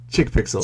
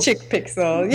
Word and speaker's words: Chic Pixel: I didn't know if it was Chic Pixel or Chick Pixel, so you pronounce it Chick Pixel. – Chick Pixel. Yeah, that's what I went with.